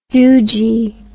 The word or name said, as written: doojee